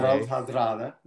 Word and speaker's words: Harald Hardrada: Harald Hardrada, a.k.a. – Harald Hardrada. No, that's right. Yeah.